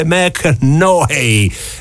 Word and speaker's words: McConaughey: Matthew, che ha un bellissimo nome, si chiama Matthew, che è come me, Mac Knohey, mi hanno detto la pronuncia è Mac Knohey, ma chi è?